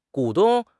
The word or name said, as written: 股东